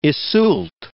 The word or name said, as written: Iseult